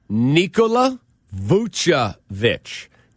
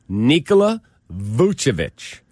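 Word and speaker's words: Vučević: He tweeted, by the way, here's the correct pronunciation. It is Nikola Vucevic. Nikola Vucevic. He said, whereas Nikola Jokic is Nikola Jokic, it's Nikola Vucevic.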